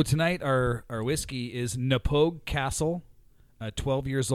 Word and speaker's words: Castle: The second word is pronounced castile. – No, it's castle. – Oh. – It's Knappogue Castle is how you say it.